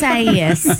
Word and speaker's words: Isaias: Isaias. You missed a syllable.